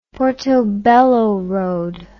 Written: Portobello Road

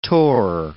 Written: tor

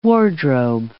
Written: wardrobe

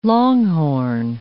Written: longhorn

